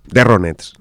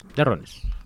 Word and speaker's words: The Ronettes: ¿Tienes algo que decir de mi pronunciación de The Ronettes, acaso? – The Ronettes. – The Ronettes. Las Ronettes.